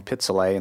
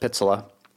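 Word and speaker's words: Pitseleh: I keep saying Pizzolay instead of Pizzola. So before you scold me out there on the internet for that, yes, I get it. It's Pizzola.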